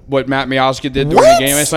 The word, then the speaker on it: Miazga: Yeah, Miazga.